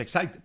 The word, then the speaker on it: Exide: Se escribe Exide, se pronuncia Exide, decía la...